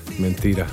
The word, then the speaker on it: mentira: Mentira. – Mentira, exactamente, ja. Ja, das darf man betonen. Das darf man fest betonen. – Mentira. – Muy bien, exactamente. – Gracias. – Also, Mentira, so stimmt's.